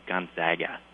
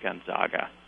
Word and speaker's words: Gonzaga: And I apologize, Scott, but it's actually pronounced Gonzaga, not Gonzaga.